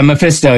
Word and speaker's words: Mephisto: Yeah, Mephisto, yeah.